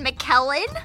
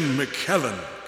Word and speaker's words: McKellen: No, I said it rhymes with Helen. It's not Ian McKellen.